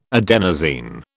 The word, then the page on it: adenosine: British English